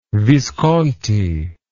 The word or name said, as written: visconti